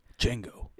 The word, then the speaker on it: Django: Django. – It's so good. – The D is silent.